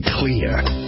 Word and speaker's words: Xlear: Introducing Clear. It's pronounced clear, but it's spelled X-L-E-A-R.